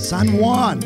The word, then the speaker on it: San Juan: Say it right. – San Juan.